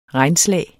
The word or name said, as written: REGNSLAG